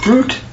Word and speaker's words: Ruth: Ruth's name is actually pronounced to this person, Root, like R-O-O-T, Root.